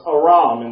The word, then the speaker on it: Aram: We pronounce that Aram, or Aram, or Aram. No, it's Aram in the Hebrew.